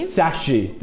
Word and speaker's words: Shashi: Shashi.